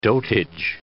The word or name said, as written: dotage